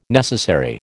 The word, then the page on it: necessary: American English